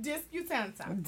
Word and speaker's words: Disputanta: Yes, Disputana. How you say it? – Disputanta. – Disputanta.